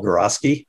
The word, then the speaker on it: Geroski: Gorosky, which is spelled G-E-R-O-S-K-I.